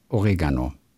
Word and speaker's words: Oregano: Es heißt, es heißt Oregano. – Genau, o vorne Oregano. die Betonung. Genau. Auf zweite Silbe.